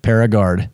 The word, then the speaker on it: Paragard: Okay, Paragard? Am I saying it correctly? – Paragard.